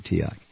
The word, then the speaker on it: Teoc: Now, it may be pronounced Teok. Somebody's going to say, come on. It's T-E-O-C. How would you say it? I say Teok.